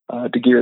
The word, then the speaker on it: Deegear: Thegear is my last name. And that's spelled D-E-E-G-E-A-R.